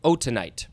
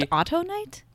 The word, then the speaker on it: autunite: It is spelled autunite. I look at the pronunciation guide and it tells me how to say it, but then we're just gonna say autunite. – Autunite. It could also be autonite. – No, because it's not auto. It's autunite. – Autunite.